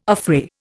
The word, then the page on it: affray: Indian